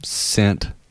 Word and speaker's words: scent: Scent. – Did you spike your milkshake? – Yeah. Yeah, dude. – All right, so the C is silent and scent.